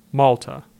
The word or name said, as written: Malta